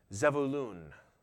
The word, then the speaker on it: Zebulun: And that would have been pronounced Zebulun. A little different than Zebulun, but I don't know if I can get used to saying Zebulun.